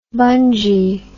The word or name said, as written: bungy